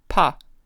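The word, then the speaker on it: pa (八): In Chinese, the number 8 sounds like or is pronounced pa.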